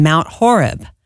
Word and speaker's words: Mount Horeb: $3,000 is added in from some awesome friends in Mount Horeb.